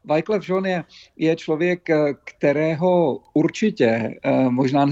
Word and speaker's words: Jean: No, možná to zní překvapivě, ale u osobnosti, jakou je Wyclef Jean, on se vyslovuje Jean, protože je z Haiti, děkuji za upřesnění.